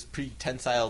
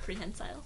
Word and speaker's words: prehensile: I mean, a mutant attacks him with his pretensile tail or whatever it is. Is that how you pronounce that word? – Prehensile.